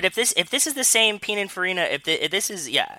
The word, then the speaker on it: Pininfarina: It's a Pininfarina Batista. – Pininfarini? – Is that how it's pronounced? – Is it Pininfarini's making their own cars now? – It's this. – It's Pininfarini. – Pininfarina. P-I-N-I-N-F-A-R-I-N-A. – Yeah, Pininfarini.